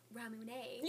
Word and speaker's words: Ramune: Looks like ramen, but I had some friends from Tokyo say that it was Ramune.